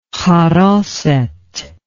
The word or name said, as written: haroseth